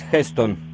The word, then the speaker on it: Heston: My last name is Heston. – Can you spell that for me? – Yes, H-E-S-T-O-N, Heston. Heston. – H-E-S-T-O-N? – Exactly, madam.